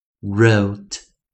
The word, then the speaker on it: wrote: 最后一个是 W R 首音，记得上一堂课有教 W R 合起来时 W 不发音，我们就称 Silent W 啊，Silent W 在自然拼读，好，那也就是 W 不发音，所以我们念 R R R wrote wrote wrote，好，再一遍呢，我们再来做重新来复一下，第一个 N N N N note, note, note.